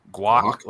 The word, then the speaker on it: guac: Ordered the cheese and how do you pronounce G-A-U-C? – Guac, like guacamole? Guac.